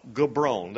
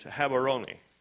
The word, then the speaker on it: Gaborone: In Gaborone, well, if you look on a map, you'll see that the capital of Botswana is called Gaborone. That's not the correct pronunciation. It's Gaborone. But in that area, there's still a hospital today that he built, that David Livingstone built in the 1800s.